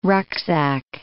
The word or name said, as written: rucksack